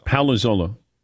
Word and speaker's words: Palazzolo: Palazzolo. So is it like Sal Palantonio? – Yeah, there you go. Okay. That should help.